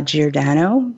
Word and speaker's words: Giordano: And on LinkedIn, if you'd like to connect, my name is spelled L O R R A I N E uh, Giordano, G I O R D A N O.